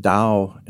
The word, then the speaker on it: Dao: The same character, Chinese character, is pronounced Dao in Chinese and is the name of the religion of Taoism.